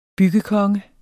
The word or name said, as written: byggekonge